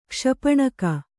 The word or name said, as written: ಕ್ಷಪಣಕ